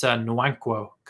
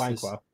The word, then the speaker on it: Nwankwo: It's Nwankwo Kanu, was how you pronounce Nwankwo. Okay. – his first name.